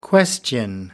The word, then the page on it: question: British English